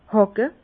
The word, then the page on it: hàcke: Bas Rhin; Herrlisheim